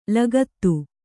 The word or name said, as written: ಲಗತು